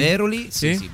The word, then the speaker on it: Veroli: Ma ha detto giusto. – Si è stato mai? – Assolutamente a Veroli? – Veroli, sì, Veroli, una ridente località.